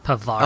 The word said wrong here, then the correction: Parvati: Other lady. – Parvati. – Parvati. Yeah. – Parvati. Yes.